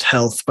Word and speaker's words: HLTH: HLTH has officially said it's pronounced health, by the way.